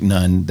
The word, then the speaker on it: Nun: Joshua, the son of Nun, and that's not N-O-N-E, that's N-U-N. And no, it's not Nun, like Sound of Music, Nun. That's, although it's spelled like that, it's actually a guy's name, Nun.